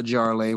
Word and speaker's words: Jarlay: It rhymes with parlay. It's the Jarlay.